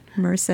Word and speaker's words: Merced: Merced.